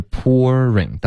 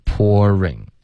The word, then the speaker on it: pouring: 可是 pouring, 大家注意一下有两种发音。 Okay? 第一是 pour, 结尾加一个儿化音 pour, 然后再来 ring, pouring. Okay? 还有一个呢是 pour, 就开头是一个 o,